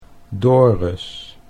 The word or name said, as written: Dorus